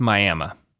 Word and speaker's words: Miami: The people of Miami, Oklahoma, don't care how it's spelled. It's Miami.